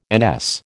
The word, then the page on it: NS: American English